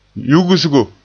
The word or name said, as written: yugsigo